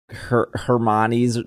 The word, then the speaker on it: Hermione: Hermione's name wrong.